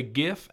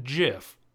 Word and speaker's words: GIF: I did say GIF, and if you pronounce it JIF, I'll kill you.